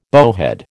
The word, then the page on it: bowhead: American English